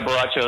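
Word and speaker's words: Borracho: The Borachio. Am I pronouncing that correctly? – Yeah, yeah, Borachio.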